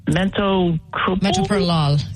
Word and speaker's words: metoprolol: The other medicine they put me on, I'm trying to remember how to pronounce it, metoprolol.